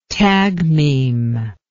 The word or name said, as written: tagmeme